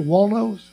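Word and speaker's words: Wallnau: Walno, I guess. It's W-A-L-L-N-A-U. Walno, that's how he pronounces it.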